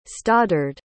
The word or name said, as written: stoddard